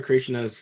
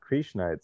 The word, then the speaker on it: Krishna: But if you want a grammar tip, you know, it's not Krishna. It's Krishna. It's a short I.